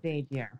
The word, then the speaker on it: Xavier: A lot of people struggle with the pronunciation there, but it's Xavier. – Xavier. – Xavier with the Z.